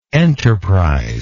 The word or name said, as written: enterpriseless